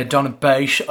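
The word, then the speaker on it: Donabate: So Barney from Donabaish. – Yeah, Donabaish. I was cracking up listening to that. That's how they say it out there, I think.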